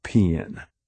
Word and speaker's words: paean: A few different friends gave me a few different opinions, so imagine my chagrin when I learned from the internet that it is pronounced paean.